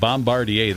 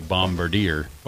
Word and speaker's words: Bombardier: And Bombardier, that is the actual way to pronounce that. Many people say Bombardier. – Bombardier, yeah. – But it's actually because it's a French-Canadian company.